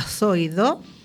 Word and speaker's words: Zoido: Dice, una juez advierte a Zoido, y bien pronunciada la Z, de consecuencias penales si no ordena mejoras en los CIE de Algeciras y Tarifa.